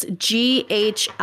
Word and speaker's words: Ghislaine: Oh, no, it's Ghislaine. – Okay, the S is what I was... Yeah, the S is not pronounced.